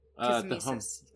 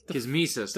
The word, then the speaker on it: Kismesis: Oh, Kismesis. Did I say that right? – Kismesis. – Kismesis.